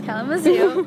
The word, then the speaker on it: Kalamazoo: Yes, Kalamazoo, Michigan, on the farm.